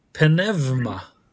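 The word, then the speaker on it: Pneuma (πνεύμα): Så, pneuma. – Pneuma. – Ja. Med V, pneuma.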